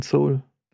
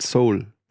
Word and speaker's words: Seoul: Das kann so ein geil, so richtig in der Untergrundwelt, in den düsteren Gassen, in den schlechten Gegenden von Seoul. Das, ja. – Ist halt kein Soul. – Ist halt kein Soul. – Seoul. – Ist halt kein Seoul.